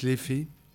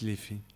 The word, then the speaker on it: Liffey: On prononce Liffey. En irlandais, ils disent Liffey. La rivière Liffey. Ça se dit Liffey.